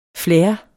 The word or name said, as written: flair